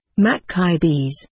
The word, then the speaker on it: Maccabees: He again corrected my pronunciation, Maccabees.